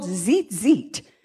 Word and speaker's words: tzitzit: She touched the fringes of His prayer shawl, also called a tallit, T-A-L-L-I-T. The fringes, those things that hang on the end of the prayer shawl, are called tzitzit. The first T is silent, T-Z-I-T-Z-I-T.